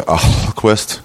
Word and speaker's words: Ahlquist: Have you ever told people my name is Jessica Ahlquist? – No, I just immediately start spelling it. Like, I'll just say Ahlquist, A-H-L.